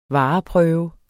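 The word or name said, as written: VAREPRØVE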